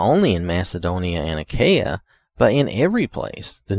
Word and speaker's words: Achaia: Now this Achaia, or I may be saying it wrong, Achaia, but I think it's Achaia.